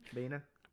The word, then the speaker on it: Baena: So it's kind of hard to know what to say about Horse Girl, the new film from writer-director Jeff Baena, I guess is maybe how you say his name. – Baena. – Baina, yeah.